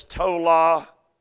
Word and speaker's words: tola: That Hebrew noun for worm, let me spell it. T-O-L-A, tola.